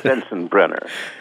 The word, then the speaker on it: Sensenbrenner: Sensenbrenner, yes. Sensenbrenner. – I never knew how to pronounce it, and thank God you did it for me. It's Sensenbrenner.